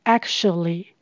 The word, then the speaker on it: actually: 母语人士常常把这个字念成 actually。 Actually，tu 是发 sh 的声音。